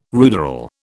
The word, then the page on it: ruderal: British English